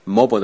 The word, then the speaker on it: mobile: Mobile 的美式发音是 mobile, 它的英式发音是 mobile。